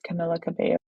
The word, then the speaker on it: Camila Cabello: Is that how you say her name? – Yes, Camila Cabello. – From where I'm from, which is a Hispanic colony, we pronounce it differently. I mean, that's a familiar surname, but we would say it differently.